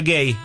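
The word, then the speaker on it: Guéhi: Conza, y se escribe Gehi, pero se pronuncia Gay, el central, junto con Nico O'Reilly o Spence.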